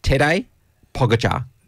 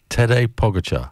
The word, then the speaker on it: Tadej Pogačar: Tadej Pogačar. – Tadej Pogačar.